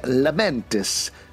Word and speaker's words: Lamentis: I don't want to talk about my hat, but I do want to talk about Loki Episode 3, Lamentis, that just dropped on Disney Plus. – Yeah, hopefully that's right. – That was perfect pronunciation.